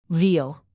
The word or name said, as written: veal